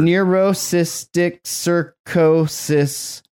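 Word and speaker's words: neurocysticercosis: Neurocystic sarcosis.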